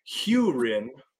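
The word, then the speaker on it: Hurin: So according to the glossary in The Great Hunt, first off, his name is pronounced Hewrin, H-E-W. – That sounds like some lawn talk. – I-N. Hewrin, And here, the definition is as follows.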